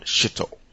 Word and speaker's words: Shito: It's called Shito. It's spelled S-H-I-T-O. In a local language, the same word in English without the last letter sounds disgusting or something else, doesn't it? Like shit, S-H-I-T.